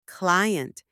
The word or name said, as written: client